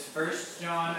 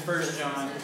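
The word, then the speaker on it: 1 John: And just so you know, so the, the name of the book is First John. That's how you say it, even though it says One John, we say First John.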